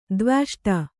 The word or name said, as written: ದ್ವ್ಯಷ್ಟ